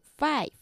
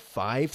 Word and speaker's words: five: One, two, three, four, five, six. – One, two, three, four, five, six. – Good. Five, con V, una V que se pronuncia. – Five. – Not five, five, okay? – Okay.